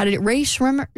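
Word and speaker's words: Rae Sremmurd: Yeah, Ray Shrimmer. – Yeah, go with that. That's better. – It's spelled S-R-E-M-M-U-R-D, Ray Shrimmer.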